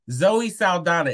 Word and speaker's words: Zoe Saldana: But her name is Zoe Saldana. Is that how you say it? Mm-hmm. Zoe Saldana.